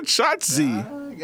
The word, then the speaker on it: Shotzi: I said Shotzi. – Shotzi? That's her name, Shotzi. – Yeah, you said it right that time. – I said Shotzi.